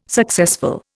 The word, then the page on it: successful: Indian